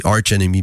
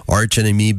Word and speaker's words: Arch Enemy: Un groupe qui mélange efficacement black metal mélodique, death metal mélodique, groove metal euh, pour les fans d'Arch Enemy, Arch Enemy pardon, je, prononce toujours euh, mal le nom, Arch Enemy,